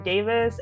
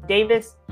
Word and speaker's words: Davis: So if you can find me on Instagram at Juakerra Davis, and because people often ask me, how do you spell your name? Davis, really easy. D-A-V-I-S. Juakerra, and spell it slow. J-U-A-K-E-R-R-A.